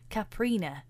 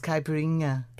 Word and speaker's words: caipirinha: I have always said caprina, and there's an extra syllable in there. You say it. – Yeah, it's caipirinha.